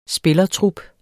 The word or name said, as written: spillertrup